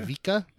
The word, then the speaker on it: Ivica: Ivica.